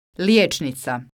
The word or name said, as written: lijecnica